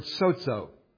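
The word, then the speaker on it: sozo (σώζω): The first word that you might recognize, and this is one every Christian should really know, the Greek word sozo. S-O-Z-O is how we would spell it in English. We wouldn't use the Greek letters because that would be, well, Greek to us. But sozo. If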